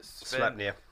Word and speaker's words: Sleipnir: He gave Loki's children are the horse that Odin, I can never pronounce it, Sleipnir.